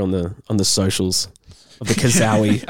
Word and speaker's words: cazaui: I'll spell the last name because I might pronounce it wrong. C-A-Z-A-U-I. – I'm just going to... Yeah, kazawi works.